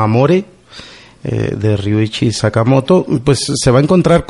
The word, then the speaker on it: Sakamoto: Bueno, no lo sé Sakamoko. – pronunciar. Sakamoko, ¿verdad? Para, para los mexicanos, pero sí, Ryuichi Sama- Sakamoto. Y mm,